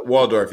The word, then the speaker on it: Waldorf: at the Waldorf. Am I saying that right? Because I only been there once. – Yeah, the Waldorf, yep.